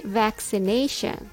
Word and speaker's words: vaccination: When you say vaccination, the stress is on nay.